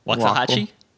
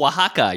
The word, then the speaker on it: Oaxaca: I don't know how to say that word. Oaxaca? – Waxahachie? – Is that how you say it? – Oh, Oaxaca. You're talking about the state in Mexico. – Yeah, Oaxaca.